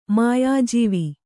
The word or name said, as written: ಮಾಯಾಜೀವಿ